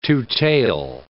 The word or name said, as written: two-tail